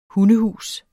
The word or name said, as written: hundehus